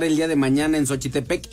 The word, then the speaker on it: Xochitepec: Entonces mañana en la presentación allá en Cuernavaca, no en Xochitepec, donde jugaban los colibríes.